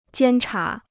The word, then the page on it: 监察: jiān chá